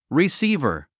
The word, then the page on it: receiver: risíːvə r; リィスィーバァ